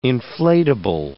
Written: inflatable

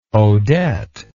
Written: odette

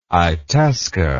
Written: itasca

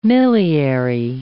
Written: milliary

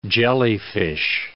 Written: jellyfish

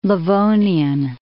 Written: Livonian

